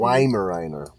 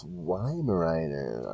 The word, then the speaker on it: Weimaraner: It's it's Weimaraner.